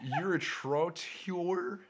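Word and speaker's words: uritrottoir: It's urotrotior. It's a combination of urine and what was it? Pavement, I believe something like that.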